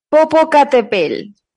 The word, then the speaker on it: Popocatépetl: Popocatepel. Popocatepel, Joder. – que significa monte que humea, básicamente. – En la canción, ¿no? Popocatépetl. O, bueno, Popocatepel.